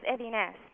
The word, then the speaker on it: Edines: It's Edines. – Edines. – Accent Edines. On the last Edines.